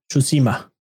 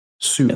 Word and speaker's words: Tsushima: And then not long after that, PlayStation came out and showed off more of Ghost of Tsushima. – Yeah. – Which, yes, the T is silent. – But the D is silent. Tsushima.